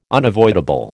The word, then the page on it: unavoidable: American English